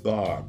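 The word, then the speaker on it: bar (ברא): It's pronounced bar, B-A-R-A, which means created.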